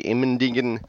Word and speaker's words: Immendingen: I went to the Immendingen test facility. And is that really how you say that, or did you mutilate that? – Nope, that's how they say it.